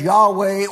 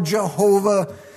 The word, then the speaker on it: Yahweh: YHWH, pronounced either as Yahweh or Jehovah.